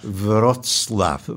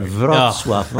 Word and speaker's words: Wrocław: Wrocław. This is the... – I would have gotten that right. – Wrocław.